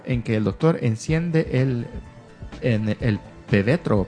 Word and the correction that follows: pebetero: Pebetero de las Olimpiadas del 2012.